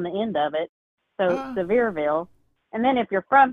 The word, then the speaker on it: Sevierville: So we're here in, now I know we're going to say this wrong, but is it Sevierville, Tennessee? – Sevierville, yes.